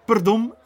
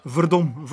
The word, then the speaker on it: verdomme: Je hoorde niets anders dan perdon dus. Dan moet je weten dat de, in Indonesië de, de V als een P wordt uitgesproken. Een, selfie is een selfie. Dus perdom is verdom, verdomme.